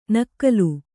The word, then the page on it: ನಾಕುಲಿ: kannada